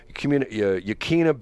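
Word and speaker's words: Yaquina: Talks about five radio stations owned by Yakuna. I guess you'd pronounce it. It's Yakuina. Wakania Bay Communications. – Yeah, Yakuna Bay Communications. – Yakuna, okay.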